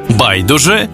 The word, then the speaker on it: байдуже: 5 українських слів із подвійним наголосом. Байдуже, байдуже, завжди та завжди, мабуть та мабуть, також і також, помилка і помилка.